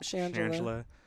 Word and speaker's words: Shangela: Shangela. I'm saying her name right? – Yep. Shangela.